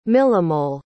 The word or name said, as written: millimole